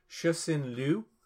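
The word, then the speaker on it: Cixin Liu: I actually had to ask a friend of mine for proper pronunciation. It's Shusin Liu, is the author.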